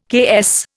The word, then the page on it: KS: Indian